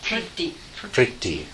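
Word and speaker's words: prīti: Anybody know how you pronounce that? – Priti. – Okay. That's the Sanskrit equivalent.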